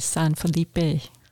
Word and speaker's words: San Felipe: Well, you would pronounce all the letters, so it'd be San Felipe.